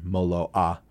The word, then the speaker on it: Moloa: All their names are Hawaiian, so you have to do that like the Hawaiian pronunciation, Moloa.